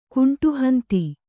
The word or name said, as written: ಕುಂಟುಹಂತಿ